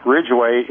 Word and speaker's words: Ridgway: Now, my name, Ridgeway, is spelled without an E, contrary to popular demand.